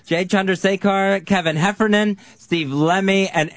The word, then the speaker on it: Chandrasekhar: Chandrasekhar is how you say Jay's last name.